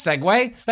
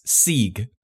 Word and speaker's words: segue: I was like, no, that's not that's segue. – Segue. So, let me start over. I've been mispronouncing for decades, like segue, which for reasons I can't explain, I always read and said as Sieg. S-E-E-G.